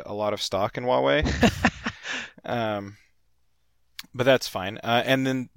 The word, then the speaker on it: Huawei: Oh, is that how you say that? I'm always like, it sounds like Hawaii to me. – It looks like that, Yeah. – yeah, but I think it's pronounced Huawei.